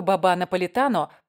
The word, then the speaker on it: баба наполитано: На самом деле мало того, что баба наполитано произносится с ударением на последний слог и вообще мужского рода, так еще и родилась в Польше.